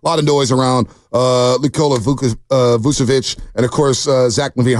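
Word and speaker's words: Vučević: You pronounced it correctly. It's Vucevic. The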